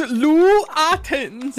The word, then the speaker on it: Lou Ottens: That is Lou Ottens.